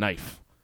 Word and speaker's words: knife: Explain that to someone, and when you're telling them that the K is silent. It's not silent in kitchen, but it's silent in knife.